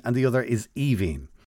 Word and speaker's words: Aoibheann: The second one is pronounced Evine. – Mm. – And I think it's because the E-A-N-N somehow affects the first five letters.